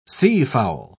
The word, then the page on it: seafowl: British English